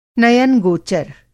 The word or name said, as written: नयनगोचर